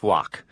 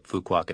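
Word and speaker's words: Phú Quốc: In Vietnamese, it's pronounced Fuoc. But all of us Westerners call it Fuquoc. It's P-H-U, new word, Q-U-O-C. – Okay, Fuquoc, N-H-I.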